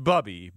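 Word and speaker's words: Bubbe: We found out pretty early in the show today that people love Bubba content. – They do. I believe it's pronounced Bubby, but...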